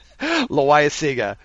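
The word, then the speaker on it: Loáisiga: How do you pronounce his last name? – Loaiza Siga. – That's it.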